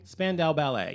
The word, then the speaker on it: Spandau Ballet: I think it's called, is it Spandau Ballet? – Spandau Ballet, yes.